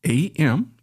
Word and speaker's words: Am: We have the word Am, which is spelled A-M. You would read it like Am, and that's what we see here.